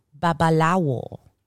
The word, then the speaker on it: babalawo: It's babalawo. Babalawo. In Spanish, babalawo still works, but most people will say babalawo. Like the W kind of disappears. Babalawo.